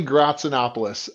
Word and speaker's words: Gratsinopoulos: No, it is pronounced Yanni Gratsinopoulos. Which, when you have more vowels than consonants, is not a good thing.